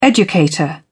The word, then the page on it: educator: British English